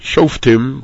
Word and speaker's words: Shoftim: We'll probably keep calling it Shoftim, but the official way to pronounce the word is Shoftim, with a shvana under the fe, makes it part of the last syllable. First syllable is sho, and then fetim, rather than the more commonly pronounced shoftim.